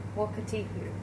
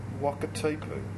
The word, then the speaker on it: Wakatipu: Now, how do we pronounce this lake? – Wakatipu. – Oh, that's good. Wakatipu. – What does it mean? That's a big shrug, which means you don't know. – Not a clue, sorry. No. – But you've done very good. – But well pronounced. Well pronounced. – Yeah, it's perfect.